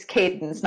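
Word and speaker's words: Cadance: It's Cadence, not Candace.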